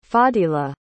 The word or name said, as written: Fadilah